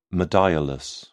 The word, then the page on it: modiolus: British English